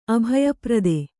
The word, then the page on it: ಅಭಯಪ್ರದ: abhayaprada